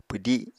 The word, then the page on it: pwdi: apatani